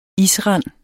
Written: isrand

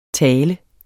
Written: tal

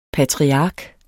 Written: patriark

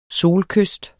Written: solkyst

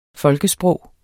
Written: folkesprog

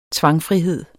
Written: tvangfrihed

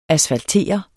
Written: asfaltere

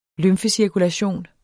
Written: lymfecirkulation

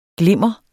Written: glimmer

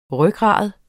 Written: rygrad

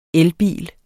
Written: elbil

